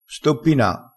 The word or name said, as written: stoppina